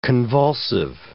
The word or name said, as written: convulsive